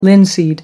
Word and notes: linseed: American English

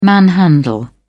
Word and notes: manhandle: British English

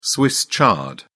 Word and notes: swiss chard: British English

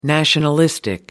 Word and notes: nationalistic: American English

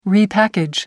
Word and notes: repackage: American English

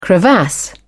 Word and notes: crevasse: British English

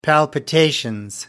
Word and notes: palpitations: British English

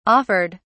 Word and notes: offered: British English

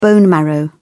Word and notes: bone marrow: British English

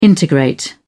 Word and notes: integrate: British English

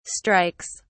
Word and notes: strikes: British English

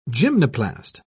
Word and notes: gymnoplast: British English

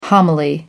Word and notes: homily: American English

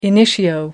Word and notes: initio: American English

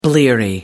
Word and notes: bleary: American English

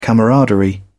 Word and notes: camaraderie: British English

